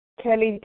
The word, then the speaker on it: Kelly: Kelly T.